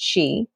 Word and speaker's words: qi: There, when we talk about qi, so depending on which specific culture we're in, depends on how we spell. So there's qi, there's chi, there's ki, and it all is pronounced qi.